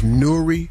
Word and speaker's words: Nuri: His name is Nuri Mohammed. N-U-R-I. Nuri Mohammed.